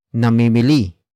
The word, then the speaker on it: namimili: Word for word it's namimili, sina, Kelvin, at, Brian, ng, muebles.